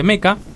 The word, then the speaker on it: Mecha: Meca, que es, o Mecha, se, escribe Mecha, pero se pronuncia Meca, que tiene presencia importante en los robots y en muchas ocasiones son gigantes y tripulados por personas.